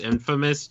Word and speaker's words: infamous: And it's pronounced infamous, just so you know.